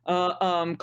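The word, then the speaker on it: Yōtei: Yotei. God, I can never remember how to pronounce it. – Yotei.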